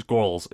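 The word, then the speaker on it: Guwls: but it's G-U-W-L-S. It's Gwarls. It's very, yeah, Gwarls.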